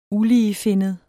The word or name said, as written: uligefinnet